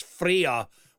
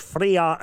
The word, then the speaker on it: Freyja: I would love to hear it as Freya. I think I pronounced that correctly. Yeah, F-R-E-Y-J-A. – Oh. – Would it be a soft J sound? – I think it would be. That sounds like it's Freya with a bit of a garlic bit.